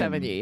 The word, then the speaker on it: Sevigny: It's Sevigny.